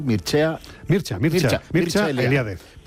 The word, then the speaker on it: Mircea Eliade: El rumano, ¿cómo lo pronuncias tú? – Mircea Eliade.